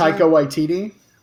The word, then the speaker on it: Taika Waititi: Well, I liked everything that – is it Taiki Waititi? How do you pronounce it? – Taika Waititi.